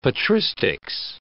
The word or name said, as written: patristics